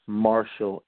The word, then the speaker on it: Marshall: G-R-A-Y-S-O-N, Marshall, M-A-R-S-H-A-L-L, Junior, J-R, .com, Grayson Marshall, Junior.